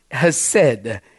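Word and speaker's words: hesed (חסד): The Hebrew word is hesed, spelled C-H-E-S-E-D. The C is silent.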